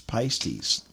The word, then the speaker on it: pistis: The Greek term for faith is pistis, P-I-S-T-I-S. And you could also pronounce it pistis.